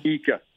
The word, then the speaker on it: Ika: Siaki Ika. – Oh, yeah. – Ika.